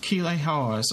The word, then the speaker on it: Keeley Hawes: Keeley Hawes. – Keeley Hawes.